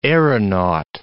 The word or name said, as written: aeronaut